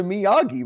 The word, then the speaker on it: Miyagi: Like, the G is usually a hard G. It's a Miyagi, right?